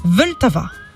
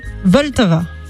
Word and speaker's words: Vltava: Et le fleuve qui coupe cette ville en deux s'appelle Vltava. – C'est comment le nom du fleuve? – Vltava. Alors j'ai cherché, je vous avoue, hein, j'ai demandé... Comment ça se prononçait? Parce que ça s'écrit V-L-T-A-V-A.